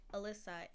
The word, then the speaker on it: Alyssa: Okay, my name is spelled like Alyssa, A-L-Y-S-S-A.